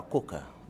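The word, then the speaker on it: Coker: J-U-S-U-C-O-K-E-R, Kuka.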